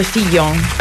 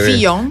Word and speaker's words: Fillon: Ho sbagliato la scorsa volta. – Doppia L si legge Fillon.